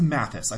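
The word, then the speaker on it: Matthijs: Okay, so next up was my pick, which is written by Eric Heisserer and directed by Mathis? – I think it's Mathis. I think the J is silent. – Okay. The soft J.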